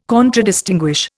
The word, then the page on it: Contradistinguish: Indian